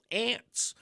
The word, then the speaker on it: Arndt: whose name is spelled A-R-N-D-T, but is pronounced ant.